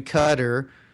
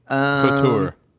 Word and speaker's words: Qatar: Oh, some people call it Qatar. – Yeah, you called it Qatar. So. – It's pronounced that way. I've heard people say different ways, but Qatar's, I think, the actual way it's supposed to be said.